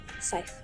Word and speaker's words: Seif: Thank you so much to Abby Seif. – Oh, wait, okay. Abby Seif? – Seif. – Seif, okay. – It rhymes with life.